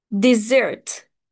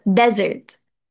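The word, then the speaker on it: desert: فخلينا نفرق بين النطق. الحلوى بتتنطق ديزرت ديزرت ديزرت خلاص، أما الصحراء بتتنطق ديزرت ديزرت. في فرق كبير قوي ما بين الاتنين.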